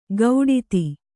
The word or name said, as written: ಗೌಡಿತಿ